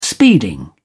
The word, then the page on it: speeding: British English